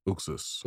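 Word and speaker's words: Uxas: Uxus.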